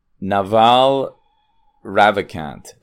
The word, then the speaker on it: Naval Ravikant: Today, I'm reviewing podcast 1309, Naval Ravikant.